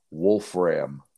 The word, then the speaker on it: Wolfram: We're going to call him Grant Wolfram. – Yes. – Full stop. Full stop. Yeah, stop Wolf between the F Ram. And the R.